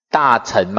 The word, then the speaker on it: 乘: 成呢，这是读成还是读秤啊？大成嘛，哈，成，成呢就是运载的意思。